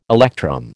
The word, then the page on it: electrum: American English